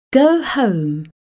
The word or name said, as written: go home